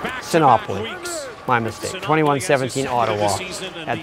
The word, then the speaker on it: Sinopoli: No, it is Sinopoli. As soon as I read it, I knew, wait a minute, that's not right.